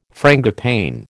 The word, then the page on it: frangipane: American English